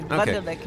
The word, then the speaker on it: Wunderlich: And before that, on the other side of the thing, the spectrum, I guess, is Klaus Wunderlich. Am I pronouncing that right? – I would go with that's okay. Wunderlich is fine.